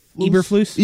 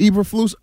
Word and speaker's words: Eberflus: Eberflus? – Eberflus.